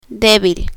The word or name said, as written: debil